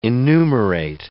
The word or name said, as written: enumerate